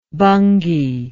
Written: bhungi

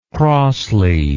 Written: crossly

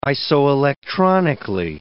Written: isoelectronically

